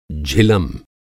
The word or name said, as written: झिलम